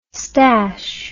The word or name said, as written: stache